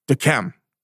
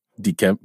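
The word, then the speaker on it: Yquem: Here's a wine that I can't pronounce properly that is well known. Because there's a Y in front of it. Dechem. – Chateau Dechem. Dechem. – It's very hard to pronounce. – Can't do it either. What is that combination? Y-Q-E-M?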